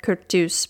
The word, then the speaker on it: Curtius: which looks like it's spelled Curtius, but I'm going to go with Curtius because Forvo told me to.